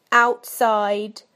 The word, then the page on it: outside: British English